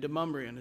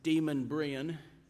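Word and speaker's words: Demonbreun: And Demumbrian is a hard word to pronounce. My GPS gets it wrong every time. The GPS calls it Demon Brian.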